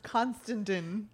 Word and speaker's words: Constantin: It's like Costandin. – It's Constantin. Like Constantine. – Yeah. – It's spelled so weird that it's like C-O-N-S-T-N-D-I-N.